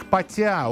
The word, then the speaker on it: потя: Непутевый человек потя, ударение на я, это в Курганской области.